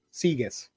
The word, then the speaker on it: sigues: In this case, the U, you don't have to pronounce it. Sigues. – It's a hard G? Sigues.